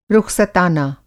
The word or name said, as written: रुख़सताना